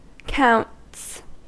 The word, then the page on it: counts: American English